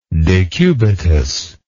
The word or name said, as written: decubital